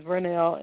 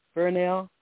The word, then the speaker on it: Vernell: My first name is Vernell, and it's spelled with a V, like in Virginia. Vernell.